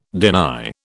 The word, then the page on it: deny: British English